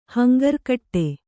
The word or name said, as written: ಹಂಗರ್ಕಟ್ಟೆ